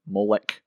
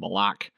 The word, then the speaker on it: Moloch: Speaking of being far from Christianity, let's talk about the Canaanite god Moloch, or Molech. I'm not sure, I've heard pronunciations different ways, but I'm going to go with Moloch. If you want to look it up, it's spelled M-O-L-O-C-H.